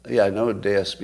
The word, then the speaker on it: Deus: D D-E-U-S is pronounced Deus, not deuce.